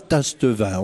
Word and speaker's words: Tastevin: Alors, ça s'écrit Tastevin? – Alors, on ne dit pas Tastevin, on dit Tastevin. Ben, tâter le vin, c'était le goûter.